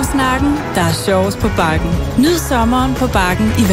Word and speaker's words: Bakken: Bakken? Zo wordt het dus blijkbaar uitgesproken in het Deens.